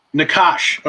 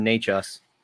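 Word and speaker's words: Nečas: Martin Necas, I believe is how you say his name, N-E-C-A-S. Nakash. Okay, thank you. I appreciate that's how they've been saying it on the radio anyway.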